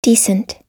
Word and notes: decent: American English

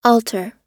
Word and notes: altar: American English

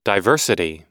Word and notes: diversity: American English

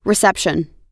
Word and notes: reception: American English

